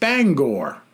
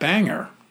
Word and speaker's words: Bangor: And then she says, and my pet peeve, Bangor, Maine, is pronounced Bangor, not Banger.